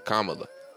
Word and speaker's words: Kamala: We pronounce her name Kamala. Because we think that it's a black name. – Right. – It's not that. – Yeah, it's Kamala. – Kamala. – Yeah, Kamala.